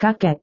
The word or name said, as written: kakek